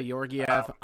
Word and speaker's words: Georgiev: I mean, Georgiev, I'm hoping I'm saying that right. I'm probably butchering. – Yeah, Georgiev.